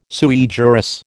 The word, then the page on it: sui juris: American English